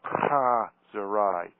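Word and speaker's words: chazerai: pronounced Chazarai, incidentally more or less translated as crap or rubbish or sometimes, I am told, as bullshit. Much mucus involved in the contraction of the thorax. The "h" is guttural.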